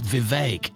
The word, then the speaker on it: Vivek: All of a sudden, everybody everywhere on all the shows, it seems like all at once, they got a memo. It's pronounced Vivek.